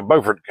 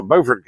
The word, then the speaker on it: Beaufort: We have a Beaufort that's spelled B-E-A-U-F-O-R-T. South Carolina has a Beaufort, which is spelled the exact same way. But South Carolina's is Beaufort, North Carolina's is Beaufort.